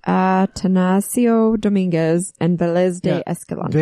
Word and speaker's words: Dominguez: As far as the white man goes, the first actual visitors were Escalante and Dominiquez. Am I saying that right? – Dominguez.